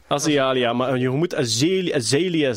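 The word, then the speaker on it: Azealia: Er zijn nog dingen die uh, Ja, we gaan moeten aan Azialia Banks eh, vragen, denk ik. Eh, of ze nog samen zijn. – Azalea. We zijn die haar naam echt al twintig keer verkeerd aan het uitspreken.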